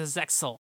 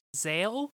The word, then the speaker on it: Zexal: Moving on to Zexal. And some people will tell you that it's pronounced Zael. Do not believe them. They're idiots.